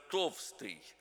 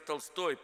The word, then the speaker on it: Толстой: Некоторые из нынешних дикторов произносят его имя как Лев Толстый. Но это Лев Толстой, писатель.